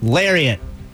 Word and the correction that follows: laureate: Laureate. – Laureate. – Oh. – That's how you pronounce that.